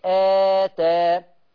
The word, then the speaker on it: آتى: يبقى الكلمة الأولى أنا عايز عينكم معايا قوي، الهمزة على ألف، إذا ما فيش مد، أتى آ آ أتى، بنتقل من همزة فتحة آ إلى تاء أتى. لكن الكلمة الثانية همزة ثم ألف، يعني الهمزة هتنطق وبعدين الألف تأخذ تقرأ بمقدار حركتين آتى آتى.